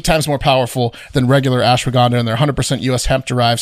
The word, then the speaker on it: ashwagandha: Just say it faster. – Ashwagandha. – Yeah. – Say it like you nailed it.